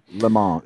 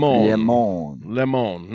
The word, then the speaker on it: Le Mans: Le Mans, if you want to be frank. – Le Mans, not to be confused with lemon.